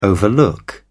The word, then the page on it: overlook: British English